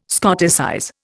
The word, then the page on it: Scotticize: Indian